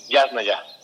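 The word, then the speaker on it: Yásnaya: Tiene acento en la primera A, Yasna ya. – Yasnaya, sí, qué bonito de verdad llevan los dos la reflexión, van conociendo a las personas.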